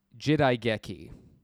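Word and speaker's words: Jidai Geki (時代劇): And there's even a word in Japanese, which is kind of a tantamount to period drama, and it's pronounced Jidai Geki.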